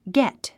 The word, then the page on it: get: gét; ゲット